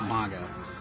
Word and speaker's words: manga: But it technically should be pronounced manga.